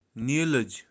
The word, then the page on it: nilij: niluj